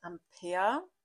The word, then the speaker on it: Ampère: Sie wurde nach einem französischen Forscher benannt und wird Ampere mit A-M-P-E-R geschrieben ausgesprochen.